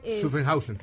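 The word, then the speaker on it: Zuffenhausen: Otra de ellas es que para su fabricación se invirtieron más de 6 mil millones de euros para adaptar esta fábrica, que no sé cómo se pronuncia, Sufen, Sufen algo, en Alemania. – Sufenhausen. – Exactamente.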